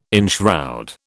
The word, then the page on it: enshroud: British English